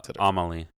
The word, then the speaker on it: Amelie: Amelie, how do we say it? You said it perfectly. – It's Amelie.